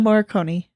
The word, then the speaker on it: Morricone: Ennio Morricone, is that am I saying that? Ennio Morricone.